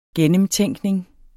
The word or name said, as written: gennemtænkning